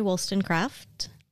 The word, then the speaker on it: Wollstonecraft: Like, so you pronounce it like we do the suburb, Wollstonecraft. – I think so, yeah.